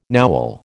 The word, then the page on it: Nowel: American English